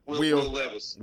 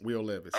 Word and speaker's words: Will Levis: Y'all have to refresh me on his name, but Will Levis. Will Levis.